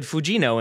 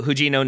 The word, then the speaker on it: Fujino: I would have said Fujino until I learned that apparently Japanese doesn't have an F. Instead, it's a H sound. So Aoba Fujino, 19-year-old forward for Tokyo Verde Beleza.